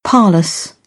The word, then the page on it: parlous: British English; ɑː